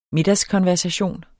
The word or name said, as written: middagskonversation